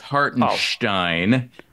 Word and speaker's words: Hartenstein: The names he had on this list were Kobe White, Denny Avdija, and Isaiah Hartenstein. So that leads to this question. Have you heard of any of these people? – Well, first of all, it's Avdija. Second of all, it's Hartenstein.